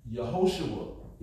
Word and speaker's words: Yehoshua: Yehoshua is how you pronounce it.